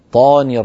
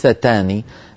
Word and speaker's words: الشيطان: الشيطاني, الطاء مفخمة, الله يبارك فيك يا أختي, لا تقولي الشيطاني, الشيطاني هذه صارت تاء وهي طاء. أعوذ بالله من الشيطاني طاني الرجيم وليس تاني.